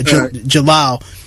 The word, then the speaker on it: Jalal: Am I saying his name correctly? Am I pronouncing that correctly? Yeah, Jalal.